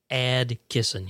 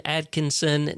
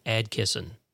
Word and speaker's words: Adkisson: I am your humble host, Donovan Atkisson. Yes, that's how you pronounce that last name. You'll probably hear Atkisson, Atkinson. No. It is A-D-K-I-S-S-O-N, Atkisson.